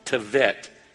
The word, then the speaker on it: Tevet: Now, it's spelled like it sounds like it should be T-E-B-E-T-H, Tebeth, but that B is actually a V sound. This is on the Jewish calendar. It is the month Tevet in the 7th year of his reign.